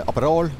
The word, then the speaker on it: Aperol: Og nå er det da en eh, det kalles det Aperol. Eh, Aperol, riktig uttalt.